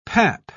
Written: pap

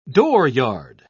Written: dooryard

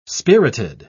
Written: spirited

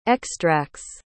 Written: extracts

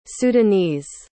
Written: sudanese

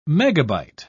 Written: megabyte

